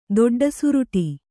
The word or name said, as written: ದೊಡ್ಡಸುರುಟಿ